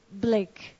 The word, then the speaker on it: Blicq: Please welcome, give a nice, warm, first-time welcome to Edward Blicq. Did I say that right? – Yes. – And that's B-L-I-C-Q.